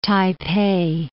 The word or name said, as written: Taipei